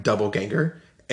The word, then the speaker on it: doppelganger: You said it, and actually the way you pronounce it sounds like double ganger.